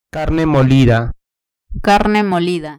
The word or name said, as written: carne molida